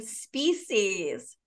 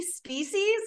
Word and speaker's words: Speesees: Okay, Organic Baby Clothing Company named Species. And it's spelled S-P-E-E-S-E-E-S. Okay, there's so many things wrong here. Okay, rule number one, your company name should not rhyme with feces.